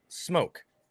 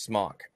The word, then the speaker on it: SMOK: Like my wife vapes, and she has a mod, and on the mod, it's spelled S-M-O-K. – Okay. – I say that's pronounced smoke. But there's other people that say, you're saying it wrong. It's pronounced smock. I'm like, no, even the website will tell you it's pronounced smoke as if it had the E there.